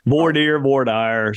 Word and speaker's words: voir dire: I said it wrong, but yes. – No. You said it exactly correctly. – Okay. I thought I might have pronounced it wrong. – No. Voir dire.